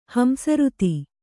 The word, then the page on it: ಹಂಸರುತಿ: hamsaruti